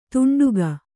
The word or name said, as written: ತುಂಡುಗೈ